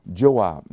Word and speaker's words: Joab: There's one gentleman in the book of Samuel by the name of, let me pronounce it right, Joab, J-O-A-B, Joab.